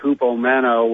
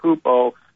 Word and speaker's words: hupomeno: There's the Greek word hupomeno, which that's in the Koine pronunciation,